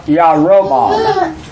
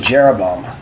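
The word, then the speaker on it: Yarobam (ירבעם): Now look at Rob, R-O-B. Okay, Rob. And just forget the other O, Om. So his name is really Yarobam. Yeah, not even close to Jeroboam, where we get Jeroboam. But that's the actual pronunciation of the name.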